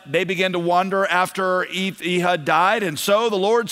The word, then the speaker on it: Ehud: Again, the Israelites did evil in the eyes of the Lord now that Ehud was dead. I think that's the way you pronounce that in Hebrew, is Ehud. I would read it Ehud.